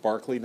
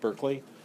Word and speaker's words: Berkeley: If you've read the Bishop Berkeley, one thing you should know is it's pronounced Berkeley, not Berkeley.